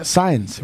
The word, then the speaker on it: Saenz: How do you spell signs? – S-A. – How do you spell León? – S-A-E-N-Z. – Oh, that okay. So the A and the E is like an I.